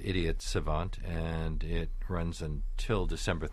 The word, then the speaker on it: idiot savant: He's also playing the title role in Richard Foreman's Idiot Savant, or Idiot Savant, if you're doing the French pronunciation.